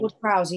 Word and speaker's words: Ward-Prowse: The first one, but even the really close one, I think, how do you say his surname? Prowse? – Whatever. Prowse, Ward-Prowse, I think. – Ward-Prowse, yeah.